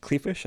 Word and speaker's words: Kleefisch: Rebecca Kleefisch, I think I'm saying that right. – Um, you are has, as a former resident of Wisconsin. There you go. Rebecca I think Kleefisch.